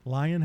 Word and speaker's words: Lyon: This gentleman's name, Lyon Tyler Jr. Lyon, how you spell that? L-I-O-N? – L-Y-O-N. – Oh, okay, Lyon.